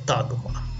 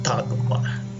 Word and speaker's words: Тагма: Это ролевая игра, которая называется Тагма. Именно с ударением на первый слог. Тагма.